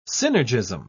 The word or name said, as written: synergism